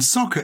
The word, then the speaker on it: soccer: And soccer is a diminution of the word association. – Yes. Yes. With, instead of the soft C, you have the hard C.